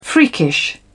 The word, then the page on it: freakish: British English